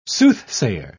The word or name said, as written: soothsayer